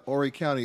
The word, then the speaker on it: Horry: Oree County and the coast. Much to their surprise, after a while... – And by the way, Oree County is where we are right now. – Oree County, we are here. – H-O-R-R-Y, not to be mispronounced.